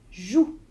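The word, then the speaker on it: jouent: Je joue.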